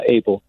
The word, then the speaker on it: Aible: And Steve, is your last name pronounced Abel? How are you? – I am well, and yes, that is how my last name is pronounced. I'm not sure that anyone has ever spelled it correctly on the first shot, but yeah, Abel, pretty easy. – A-I-B-L-E, and you are the Senior Director of Animal Behavior and Training for the Shedd Aquarium.